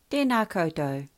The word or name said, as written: tena koutou